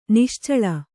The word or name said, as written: ನಿಶ್ಚಳ